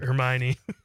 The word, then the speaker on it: Hermione: Am I saying that right? I didn't see that. Hermione? What is that word? – Hermione.